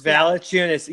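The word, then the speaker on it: Valančiūnas: Valanciunas.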